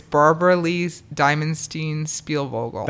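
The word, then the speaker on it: Barbara Lee Diamonstein-Spielvogel: It's Barbara Lee Diamondstein Spielvogel.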